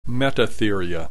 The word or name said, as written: Metatheria